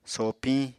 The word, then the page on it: sopiñ: Apatani